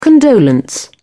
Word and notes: condolence: British English

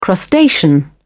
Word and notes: crustacean: British English

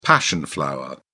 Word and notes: passion flower: British English

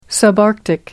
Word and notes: subarctic: American English

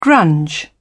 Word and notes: grunge: British English

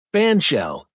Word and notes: bandshell: American English

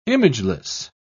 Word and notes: imageless: British English

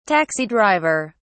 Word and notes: taxi driver: British English

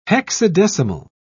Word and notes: hexadecimal: British English

